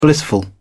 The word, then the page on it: blissful: British English